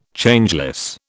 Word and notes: Changeless: British English

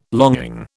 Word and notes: longing: British English

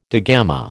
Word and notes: digamma: American English

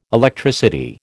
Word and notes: Electricity: American English

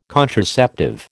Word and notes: contraceptive: American English

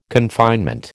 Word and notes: confinement: American English

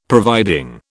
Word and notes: providing: British English

